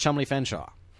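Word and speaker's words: Cholmondeley Featherstonehaugh: and it says Cholmondeley Featherstone Haw, and it's pronounced Chumley Fenshaw.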